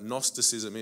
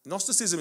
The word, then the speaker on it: Gnosticism: I don't know if any of you know what Gnosticism is. It's a strange word. It starts with a silent G, believe it or not. Gnosticism is an ancient philosophy,